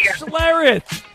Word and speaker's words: Schlereth: Yes, Mark Schlereth. – Celery. Yeah. You butchered his last name, but we'll take that.